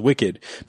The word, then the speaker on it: WCKD: WCKD is pronounced as wicked because in the book, the acronym was WICKED, standing for, this was so stupid, World and Catastrophe Kill Zone Experiment Department.